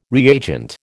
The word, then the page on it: reagent: American English